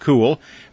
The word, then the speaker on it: Cuil: They call it Cool, C-U-I-L, pronounced cool. They say it's an old Irish word for knowledge.